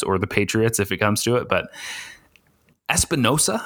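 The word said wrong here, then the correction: Epenesa: am I saying that right, from Iowa, the edge rusher? – Epinesa.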